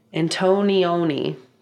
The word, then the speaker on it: Antonioni: So directors like Fellini and, ooh, I can't say his name, Antonioni.